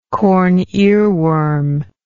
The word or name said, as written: corn earworm